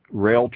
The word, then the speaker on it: Roehl: Rail Transport, that carrier that's spelled R-O-E-H-L.